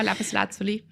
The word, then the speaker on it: Lapislazuli: Das war die Lapislazuli oder Lapislazuli, wie spricht man es richtig aus? – Genau, Lapislazuli, ja.